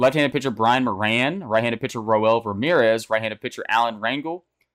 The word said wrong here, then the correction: Rangel: Or Ranhel, let me pronounce that right.